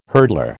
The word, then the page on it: hurdler: American English